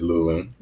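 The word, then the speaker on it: Luling: Am I saying it wrong? I keep saying Loling. Is it Loling? – It's Loling.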